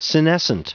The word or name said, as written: senescent